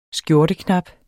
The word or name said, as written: skjorteknap